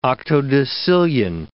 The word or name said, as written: octodecillion